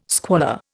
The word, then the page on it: squalor: Indian